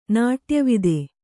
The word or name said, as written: ನಾಟ್ಯವಿದೆ